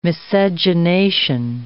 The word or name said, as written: miscegenation